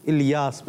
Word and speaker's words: الیاس: حضرت علامہ مولانا محمد الیاس قادری، درست تلفظ کیا ہے؟ الیاس، تو الیاس نہیں پڑھیں گے، الیاس.